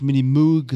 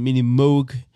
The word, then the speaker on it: Minimoog: Der Minimoog, also das ist ein Amerika, also das wird amerikanisch ausgesprochen, nicht Minimoog, sondern Minimoog äh, wird, wird, war die Revolution, also einen Synthesizer zu haben, was man transportieren kann und, äh, und so äh, hm.